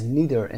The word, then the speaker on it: neither: Is neither in the question. Neither رو میتونید neither هم تلفظ بکنید.